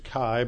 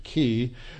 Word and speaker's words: Chi: We've studied a chiasm before, and it's from the Greek letter, used to pronounce it Chi, but now they pronounce it Ki, looks like an X.